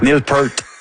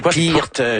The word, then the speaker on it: Peart: Neil Peart. – Non, c'est même pas ça. – Non, c'est Peart, c'est quelque chose.